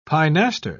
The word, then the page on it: pinaster: British English